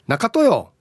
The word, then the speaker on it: なかとよー: B 中豊。はい、だからさのイントネーションですね